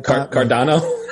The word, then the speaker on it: Cardano: How do you say it? – Cardano. – Cardano. My Cardano.